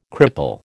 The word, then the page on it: cripple: American English